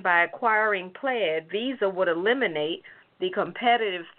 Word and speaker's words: Plaid: Well, Department of Justice is trying to block Visa's planned acquisition of a financial tech startup company called, I believe it's pronounced Plaid, P-L-A-I-D. So I'm going to call it Plaid.